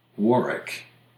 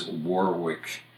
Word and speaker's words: Warwick: Warwick in the British pronunciation becomes Warwick, Rhode Island.